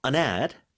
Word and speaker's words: an ad: I saw an ad. 근데 이게 an ad 붙여서 발음 좀 도와주세요. – An ad. An ad 하시면 쉬워요. An ad. – An ad. – An 왠지 n을 뒤에 붙여서 발음하는 것처럼 I saw an ad for marathon.